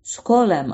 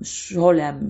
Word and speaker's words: Scholem: E, nitekim bu demin adını andığım Skolem aslında yanlış okudum herhalde. Gershom Scholem ya da Skolem e, diye okunuyor.